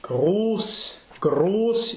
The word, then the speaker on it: groß: Groß ist der Männer Trug und List. – So this SZ, this is groß. This is a definite closed long O.